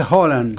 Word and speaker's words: Haaland: Escucha, Erling Haaland, que ayer nos decía en noruego se pronuncia Haaland. Haaland. Pero bueno, uh-huh. Vamos a decirle Haaland.